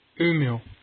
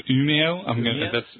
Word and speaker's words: Umeå: Umeå. Umeå. – So Umeå.